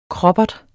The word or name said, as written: kroppert